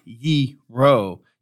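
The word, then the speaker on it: gyro: It's actually pronounced Yee-ro. You dropped the O sound quick.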